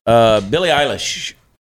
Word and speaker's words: Billie Eilish: Billie Eilish. Can I say that right? – Mm-hmm.